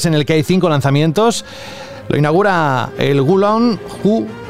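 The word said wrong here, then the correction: Wo Long: no sé cómo se dice esto, ayúdame Frank, es Gu Fallen Dynasty, ¿lo he dicho Yo bien? Digo... – Mm, Wolong.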